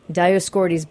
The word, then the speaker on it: Dioscorides: Dioscorides.